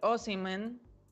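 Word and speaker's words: Osimhen: don Víctor Osimen, se, pronuncia así, no se tiene, es delantero de 21 años procedente del Lille.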